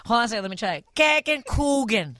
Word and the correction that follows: Käsekuchen: No. – Oh. – Käse. – Käse. – Kuchen. – Kuchen.